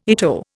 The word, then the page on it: ITO: Indian